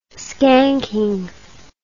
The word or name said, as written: skanking